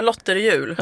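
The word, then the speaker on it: Lotrjul: Du har också drivit hashtaggen, nu vet jag inte hur jag ska uttala det, Lotterjul. – Ja, Lotterjul, ja. Brukar jag säga.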